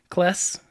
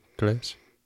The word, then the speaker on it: Claes: Yeah, because it's like, honestly, though, how do you pronounce the name? Because it's spelled C-L-A-E-S, and he's Danish. So give me the old Wikipedia. Kless? – Yeah. Kless? – Kless.